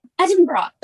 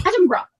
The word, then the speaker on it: Edinburgh: You have to say Edinburgh, okay? It's like a bra. – Edinburgh.